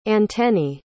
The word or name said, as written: antennae